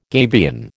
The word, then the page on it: gabion: American English